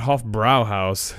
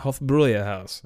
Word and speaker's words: Hofbräuhaus: I've done this so many times on the podcast, but it's not Hofbrau. Not Hofbrauhaus. There's the umlauts above the A in the brow part. So it's Hofbräu. Like, I believe that's how you actually pronounce it. Hofbräuhaus.